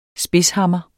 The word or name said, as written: spidshammer